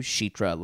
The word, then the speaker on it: Sheetra: Sheetra's name pronunciation is closer to Sheetra, like the CH sound for Chicago. So, yeah.